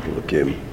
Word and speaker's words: Elohim: Elohim.